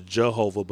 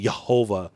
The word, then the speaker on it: Jehovah: You know, that's why we came up with Jehovah, or unfortunately the English pronunciation is Jehovah, but it's actually that J is a Yah sound, Jehovah.